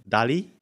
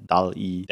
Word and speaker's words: DALL-E: DALL-E? Is that how you pronounce that? – I think so. I think it's a wordplay from WALL-E, DALL-E. – Yeah, the Disney Pixar thing.